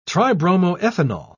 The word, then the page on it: tribromoethanol: British English